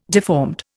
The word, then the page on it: deformed: Indian